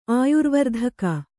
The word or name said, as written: ಆಯುರ್ವರ್ಧಕ